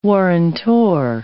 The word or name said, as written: warrantor